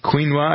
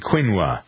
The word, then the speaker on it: quinoa: Quinoa, I guess maybe quinoa is it pronounced.